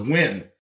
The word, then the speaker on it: Nguyen: But her name was Nguyen, right? Which is spelled like W-I-N.